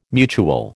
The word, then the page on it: mutual: American English